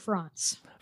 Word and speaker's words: Franz: It's actually, the difference is in the A. So oh. I pronounce it Franz.